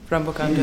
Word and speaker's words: Perampokan: Yes, and a question about your music video Perampokan 2, Perampokan, do I pronounce it right? Yeah.